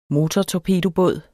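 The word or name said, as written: MOTORTORPEDOBÅD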